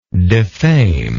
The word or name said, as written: defamingly